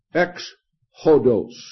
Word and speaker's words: ex hodos: In Greek, the way The words in Greek for the way are pronounced as this. Ex hodos. That's how you say Exodus.